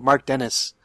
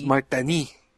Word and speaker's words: Denis: Mark Dennis. yeah, they just not, don't pronounce S's. – Mark Dennis, Mark Denis, Not Guy Bear.